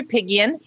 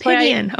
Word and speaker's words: Apigian: It's Dr. Apigian, but that's Apigian. That's why I go by Dr. Aimie. Because there's so many different ways that you can say that.